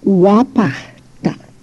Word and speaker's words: wapahta: Again, wapahta, a guttural A-H-T, wapahta, a very short A at the end of the word, wapahta.